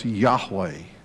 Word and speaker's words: Yahweh: It's really the old Testament word that would be pronounced Yahweh.